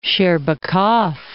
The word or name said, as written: Shcherbakov